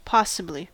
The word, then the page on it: possibly: American English